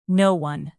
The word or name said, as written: no-one